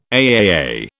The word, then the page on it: AAA: British English